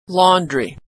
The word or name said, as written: laundry